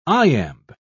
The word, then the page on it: iamb: British English